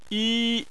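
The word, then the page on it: yi: tone 1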